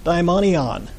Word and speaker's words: daimonion: The word demon here in demon-possessed is from the Greek word daimonion. Okay? Daimonion, it's even pronounced similar to demons.